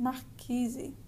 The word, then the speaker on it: marquise: So you use the R to kind of decelerate the word and come to a stop, mar, and then give the second syllable kizi, or actually the second and third, sorry. Um, and when we put them together, we get marquizi, exacto.